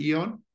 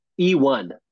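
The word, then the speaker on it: Eone: So the Eon watch, is that how you pronounce it? Eon? – E1. – E1. Okay, that makes more sense.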